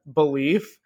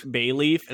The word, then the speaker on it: BALEAF: Yeah, they're called BALEAF, belief. It's just, and Charlie usually pronounces it bay like leaf. It's bay leaf, and I'm like, it's belief, it. Charlie. It's belief. Just belief in me.